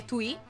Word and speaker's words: Twee: Beh, comunque niente, il nome è Twi, come avete detto voi prima, si scrive TWEE.